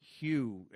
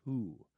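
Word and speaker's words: Hu: That's H-U, not W-H-O, like the classic Who. No, Hugh, it looks like, but it's Who, the proper pronunciation of the Mongolian Rockers, who are out with a new album next month in July called Hun.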